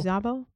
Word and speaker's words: Szabo: S-Z-A-B-O. Apologies if I pronounce Zabo. Zabo? – Yeah.